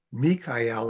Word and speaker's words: Mikael: But in general, if it's, we say it with the emphasis on the EL meaning of God, Mikael or Michael doesn't actually kill the dragon.